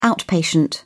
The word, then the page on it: outpatient: British English